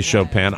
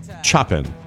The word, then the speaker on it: Chopin: Some people say Chopin. I prefer to pronounce his name Chopin.